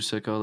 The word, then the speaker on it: Siko: Like everything, like you can even just look up Drew Sicko, like spelled like S-I-K-O.